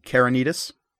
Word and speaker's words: Kerynitis: Its name is, I'm probably going to pronounce this, well okay, is, I know, it's, Carinitis. – Carinitis? – Mm-hmm. That right. is K-E-R-Y-N-I-T-I-S.